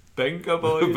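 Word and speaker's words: Vengaboys: If the ERG were more like the Venger boys... – I think you pronounce it... Benga boys.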